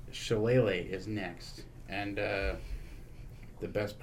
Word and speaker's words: Shillelagh: But it's apparently Shillelagh.